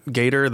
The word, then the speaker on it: Gaider: so, and you, you had already brought up David Gaider, Gaider, that's how you pronounce his name, uh, David Gaider's comments, but I was surprised to see the tweet from David Gaider.